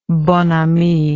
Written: bonami

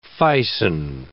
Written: ficin

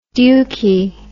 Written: dukie

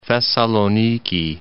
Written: Thessaloniki

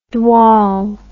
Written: dwaal

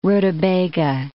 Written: rutabaga

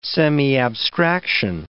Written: semiabstraction

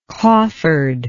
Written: coffered

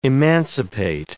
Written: emancipate

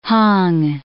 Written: hung

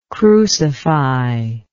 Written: crucifier

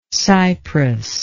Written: Cyprus

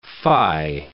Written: fie

